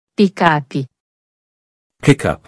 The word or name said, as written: Pickup